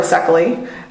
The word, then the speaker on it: Szekely: I am on Twitter, Aura Sekely. My last name is unpronounceable in Hungarian. It is spelled Szekely.